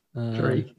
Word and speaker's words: Terik: Tariq. T-E-R-I-K. – Tariq, yeah.